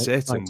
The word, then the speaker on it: seitan: S-E-I-T-E-N or something. – Yeah, it's pronounced Satan,